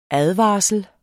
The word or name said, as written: ADVARSLEN